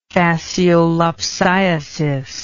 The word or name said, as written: fasciolopsiasis